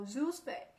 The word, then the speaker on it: Zoozve: 他把它念作叫 Zoosvay，拼写呢是 Zoozve。